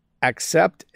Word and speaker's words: accept: Accept, aspect. Pronunciation is difficult for me. So let's do this again. I have to accept that sometimes I'm too busy. So I'm saying a little more of an A sound.